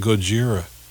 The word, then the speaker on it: Gojira: We should play some of that Gojira.